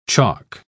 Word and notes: choc: British English

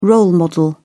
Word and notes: role model: British English

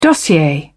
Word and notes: dossier: British English

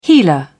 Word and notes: healer: British English

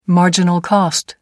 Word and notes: marginal cost: American English

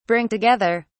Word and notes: bring together: British English

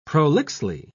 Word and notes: prolixly: British English

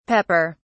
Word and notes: pepper(s): British English

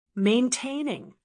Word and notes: maintaining: American English

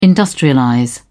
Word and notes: industrialize: British English

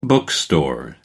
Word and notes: bookstore: British English

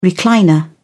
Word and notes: recliner: British English

